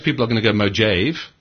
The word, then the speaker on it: Mojave: Mac OS Mojave, I think it's called, or Mojave.